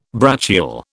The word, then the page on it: brachial: British English